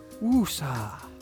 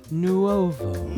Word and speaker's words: NUOVO: Which if you say it right, not like woosa. – NUOVO.